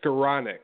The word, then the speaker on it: Skowronek: It's Skowronek.